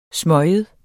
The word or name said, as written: smyge